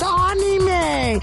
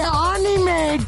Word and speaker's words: anime: You have to stop speaking whatever language you speak and pronounce it in perfect Japanese. It's anime. Say anime, damn it. Anime.